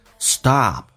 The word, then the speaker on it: stop: Stop，打开自己的口腔啊，在美式发音有啊到啊的一个转变，叫 stop。